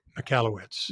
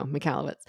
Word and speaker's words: Michalowicz: I don't know how to pronounce Mike's last name. – Michalowicz. – There we go. Michalowicz.